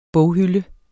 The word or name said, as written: boghylde